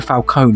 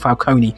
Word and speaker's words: Falcone: But the father, you know, the whole Falcone, I'm also glad of how they pronounced Falcone, you know, Carmine Falcone, not Falcone.